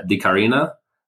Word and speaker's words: Dicarina: You are serving currently as the Chief Executive Officer of Di Carina.